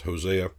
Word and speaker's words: Hosea: And He saith also in Hosea, which is O-C, is how it's said in the Greek, but it's Hosea, I will call them My people, which were not My people, and her beloved, which was not beloved.